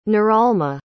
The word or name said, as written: noralma